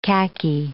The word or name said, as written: khaki